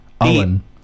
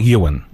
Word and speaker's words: Eoin: How do you pronounce his name? – Owen. – Owen. It's Owen. – It's Owen. – It's not Ewan. – No, it's Owen. – Spelled E-O-I-N. Three vowels in a row, why not?